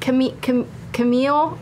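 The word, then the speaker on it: Caemiel: Our friend and artist, Cammy. I hope I'm saying that right. Camille, at Camille on Twitter.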